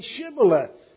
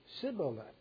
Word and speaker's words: Shibboleth: If he said, 'No,' then they would say to him, 'Then say Shibboleth.' And he would say Sibboleth, but he could not pronounce it right.